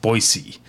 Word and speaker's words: Boise: I don't know what you would call that, but not a Z sound, an S sound, Boise.